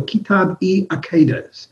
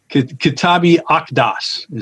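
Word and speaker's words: Kitáb-i-Aqdas: I think it's called the Kitab-i-Aqidas, or the Aqidas. – Kitab-i-Aqdas is how it's pronounced. – Kitab-i-Aqdas. – Yes, the Q is like a K, and Kitab means book.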